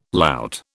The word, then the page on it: lout: British English